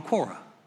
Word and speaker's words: Quora: I said, Quora. I think it's Q-U-O-R-A. I said, nobody knew how to pronounce it. It's Quora, right?